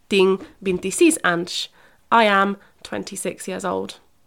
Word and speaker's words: anys: Yeah, tinc 30 anys. It's a bit of a weird one to pronounce. We think of like, it's a woman's name, Ange. So just think of a woman's name, that called a person called Ange.